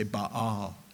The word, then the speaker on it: Baal: You're supposed to say Baal. That's how you pronounce his name.